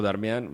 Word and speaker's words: Darmian: Eh, por cierto, ahora que has dicho Darmian,